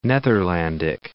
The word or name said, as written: Netherlandic